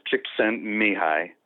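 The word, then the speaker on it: Csikszentmihalyi: It's actually pronounced Csikszent Mihai.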